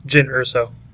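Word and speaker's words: Jyn Erso: Mispronounced the name at first, but that's okay. – Isn't it Jen? – Jen Erso. – Erso? Yeah. Whatever. Doesn't matter. – So he said Jan Ursa.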